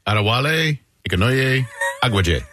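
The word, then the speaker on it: Adewale Akinnuoye-Agbaje: I've never been able to pronounce his name properly. Adewale Akinnuoyo Abaje. – That's pretty good. – You know how to say his name, right? – Adewale Akinnuoyo Abaje.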